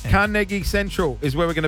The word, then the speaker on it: Carnegie: Hey guys, we've got more chances to win cash because this afternoon we are going to be at Carnegie. – No, you're butchering it again. – Damn it. – Come on, let's say, you ready? – Carnegie. We're going to be at Carnegie.